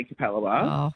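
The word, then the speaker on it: Capalaba: And it took me ages to learn how to say Cabba Labba. – Oh, well, it might not be my show you're enjoying then. – Because that's how, if you look at it, it's C-A-P-A-L-B-A, Cap-L-Labba.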